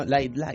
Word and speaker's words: Lite: Que bueno, que la de esto sería, la pronunciación sería DS Lite. – Sí, que ya, pero... vendría a ser como una bebida refrescante. – Like. – No, light.